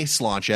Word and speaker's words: sláinte: Bringing it back to sláinte, which again is the Irish word for cheers. It's spelled kind of funny, but I knew because I've been to Dublin before how to say sláinte,